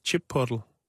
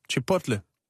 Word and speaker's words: Chipotle: så udtales Chipotle. – Chipotle? – Chipotle.